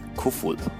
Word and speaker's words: Koefoed: So here comes a pronouncer for Susanne Kufuhl. – Which, I'd like to point out, is spelled K-O-E-F-O-E-D. – Kufuhl. me say that again. Susanne Kofod.